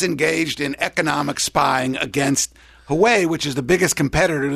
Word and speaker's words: Huawei: spying on a company called, I think it's pronounced Huawei, H-U-W-E-I, which is the biggest maker of everything from cell phones to computer systems to software systems.